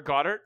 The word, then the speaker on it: Goedert: Dallas Goedert. – Okay. Or Goddard. – Yeah. – It's French. – We're pronouncing everything French today, okay? – Uh-huh. Dallas Goedert scored a touchdown today. Feet crepes for French. – Everyone. – He's French Canadian.